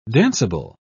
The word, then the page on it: danceable: British English